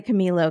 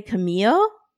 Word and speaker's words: Camilo: I think they all say Camilo. – Okay. I think you pronounce the L's.